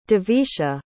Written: devisha

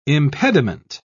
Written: impediment

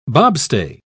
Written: bobstay

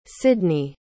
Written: Sydnie

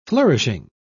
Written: flourishing

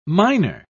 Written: minor

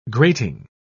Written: grating

